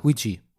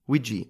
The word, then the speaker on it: Ouija: Ouija. Ouija, okay.